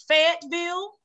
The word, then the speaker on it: Fayetteville: So it's Fayetteville. – Fayetteville. Okay. – So you pronounce every consonant in there.